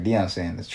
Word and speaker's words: Deion Sanders: Deion Sanders.